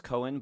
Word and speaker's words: Coan: My last name is pronounced Cohen, but it's C-O-A-N. – And we got the clue. – You got a clue. You got a clue. But people pronounce C-O-A-N wrong all the time, and I like it does.